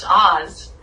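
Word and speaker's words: Oz (עז): And then that's a Hebrew word that's pronounced Oz.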